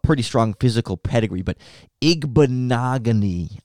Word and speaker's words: Igbinoghene: There's too many letters in there, and it looks like an Igbinoghene, but Igbinoghene is how I've been told to pronounce that last name, and it would be a fun one to have to learn in Chicago.